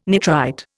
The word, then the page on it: nitrite: Indian